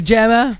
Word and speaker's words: JAMA: Now, when I say JAMA, I'm not saying pajama JAMA. – I mean JAMA. – JAMA? No, that's what it's spelled, J-A-M-A.